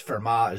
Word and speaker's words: Fermat: because Fermat is the right pronunciation, but I always learned it as Fermat's principle.